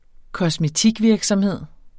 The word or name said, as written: kosmetikvirksomhed